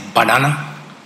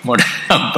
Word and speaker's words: Moderna: Whereas, remember one minister in TV, he mispronounced Moderna as Badana. You didn't hear that. But my wife heard Moderna, Badana.